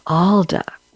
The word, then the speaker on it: Aalda: Mariann Aalda, and am I pronouncing it right? Aalda, A-A-L-D-A, is that correct? – Yes, you are.